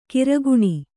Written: ಕಿರುಕಣ್

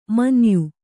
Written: ಮಾನಾಯ

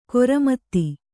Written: ಖರಾಮತ್ತು

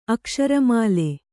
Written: ಅಕ್ಷರಮಾಲೆ

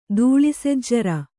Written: ದೂಳಿಸೆಜ್ಜರ

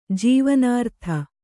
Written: ಜೀವನಾರ್ಥ

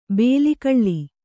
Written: ಬೇಲಿಕಳ್ಳಿ

